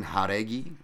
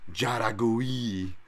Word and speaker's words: Járegui: And then lastly, but not leastly, Yasmin Járegui, not Járegui, as I fucking brutally pronounced last time, -305, comeback on Estela Nunes, +255.